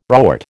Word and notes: rort: American English

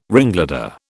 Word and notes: ringleader: British English

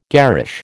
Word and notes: garish: American English

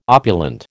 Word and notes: opulent: American English

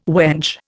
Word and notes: wench: Indian

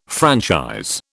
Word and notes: franchise: British English

